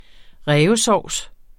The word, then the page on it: rævesovs: ˈʁεːvəˌsɒwˀs